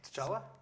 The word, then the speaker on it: T'Challa: T'Challa? – Yeah, T'Challa.